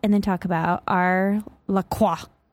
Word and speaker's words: LaCroix: A girl at Pure Bar, she said LaCroix, and I was like, I say LaCroix. She's like, yeah, that's how you say it.